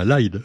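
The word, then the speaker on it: Aïd: Euh, je prononce bien LIDE. E on dit LIDE.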